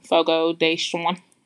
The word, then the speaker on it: Fogo de Chão: Fogo de Chão.